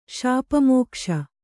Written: ಶಾಪಮೋಕ್ಷ

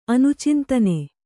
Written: ಅನುಚಿಂತನೆ

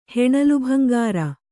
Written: ಹೆಣಲುಭಂಗಾರ

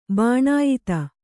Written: ಬಾಣಾಯಿತ

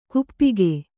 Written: ಗೋಪಿಕೆ

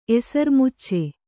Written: ಎಸರ್ಮುಚ್ಚೆ

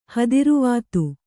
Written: ಹದಿರುವಾತು

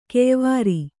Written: ಕೆಯ್ವಾರಿ

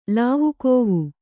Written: ಲಾವುಕೋವು

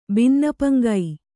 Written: ಬಿನ್ನಪಂಗೈ

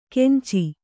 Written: ಕೆಂಚಿ

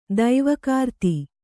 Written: ದೈವಕೃತಿ

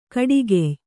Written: ಕುಡುಗೆಯ್

